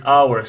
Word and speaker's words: hour: Well, that's because it doesn't start with the sound H. We as you know, it's a silent letter there. So we say hour. So it starts with the vowel sound au, with a diphthong au instead.